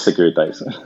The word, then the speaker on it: Securitize: En este episodio eh, está con nosotros CEO, fundador de Securitize o Securitize. ¿Cómo se pronuncia? – En inglés, Securitize.